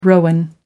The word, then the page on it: rowan: American English